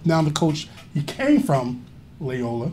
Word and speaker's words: Loyola: How do you say it? Loyola? – Loyola. – Okay, Loyola.